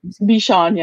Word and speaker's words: Bichon: Bichon, yeah.